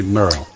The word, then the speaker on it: Merle: Sí, Merle.